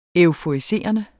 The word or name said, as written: euforiserende